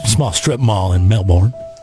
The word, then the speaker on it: Melbourne: Yeah, the idiots down under, they say Melbourne. – Oh. – But in Florida, it's Melbourne. – Yeah, Melbourne. – Like it's spelt.